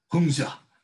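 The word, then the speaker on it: Hongzhi: Um, and I thought, wow, I, I've always been impressed by um, the old Chan master Hong Ji, who Guagu has corrected me. It's pronounced Hungja, Hungja.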